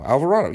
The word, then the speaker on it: Alvarado: What if that team is relatively healthy, and they get better seasons from Snell, and they get better seasons from Alvarado? Did I just say his name wrong? Alvarado. Alvarado, yeah, that's his name.